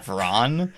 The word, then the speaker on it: Vraan: I want to talk about Vran? I don't know how to pronounce V-R-A-A-N. – Vran.